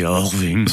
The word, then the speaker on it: Irving: Irving, Irving. Si on veut être américain, on dit Irving.